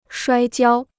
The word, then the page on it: 摔跤: shuāi jiāo